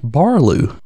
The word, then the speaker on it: Baloo: Yeah, so Mowgli is actually Mowgli, kind of like, you know, the first part of his name, M-O-W, rhymes with cow, Mow. So Mowgli and not Mowgli. And in addition, Ka the snake was supposed to be Kar the snake, and Baloo the bear should have been Barloo the bear, according to the book.